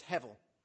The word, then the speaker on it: hevel: So the Hebrew word is hevel. And you may see that, I think I've written it on your sheet, you may see that at times spelt with a B. The pronunciation is actually with a V.